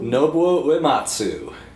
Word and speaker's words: Nobuo Uematsu: I always mess up the pronunciation, but Nobuo Uematsu? – Nobuo Uematsu.